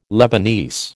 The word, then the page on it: Lebanese: American English